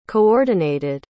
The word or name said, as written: coordinated